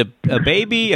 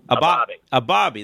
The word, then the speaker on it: Abibiy: How do you pronounce this? Is it a baby, a BB? – A Bobby. – There you go.